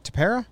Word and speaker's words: Tepera: They also got Ryan Tepera. It was a rental. Is that how you say it? Tepera? – Yeah.